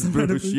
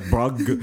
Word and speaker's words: Bruges: Oh man, no. I've said it wrong. I love it. Moments ever actually said it to me though. – No, it's Bruges. You're right. I'm just every time I see it, I'm like Brugge, Brugge.